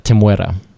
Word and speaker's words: Temuera: That's how you pronounce it, Temuera.